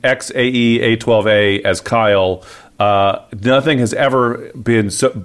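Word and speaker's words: X Æ A-12: I've been told, I did some investigation, apparently that name is actually supposed to be pronounced, do you know this? – No. – Kyle.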